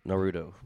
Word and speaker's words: Naruto: So my number three, Spider-Man versus Naruto.